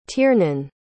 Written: tiernan

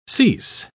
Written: cease

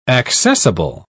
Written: accessible

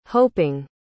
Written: hoping

